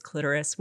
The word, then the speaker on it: clitoris: So you might be listening to this fun and scintillating conversation, wondering to yourself, "But wait, isn't it pronounced clitoris?" To which I say, "Yes, yes, it is." The other pronunciation isn't technically wrong, but the mainstream, most commonly used pronunciation is clitoris,